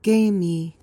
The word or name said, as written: gamy